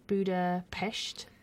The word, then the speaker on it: Budapest: Well, I'll celebrate a little break I had, which was away to Budapest, is how it should be pronounced.